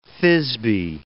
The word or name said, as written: Thisbe